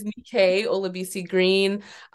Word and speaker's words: Nike Olabisi-Green: It is Nike Olabisi-Green.